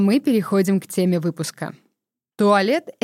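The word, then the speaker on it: toilette: Слово «туалет» происходит от французского «туалет». Мне понадобилась помощь моей ассистентки, чтобы не допустить ошибки в произношении.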